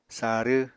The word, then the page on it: sarw: apatani